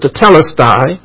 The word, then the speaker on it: tetelestai: In the Greek is tetelestai, some pronounce it tetelestai, which means he completed his mission.